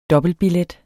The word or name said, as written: dobbeltbillet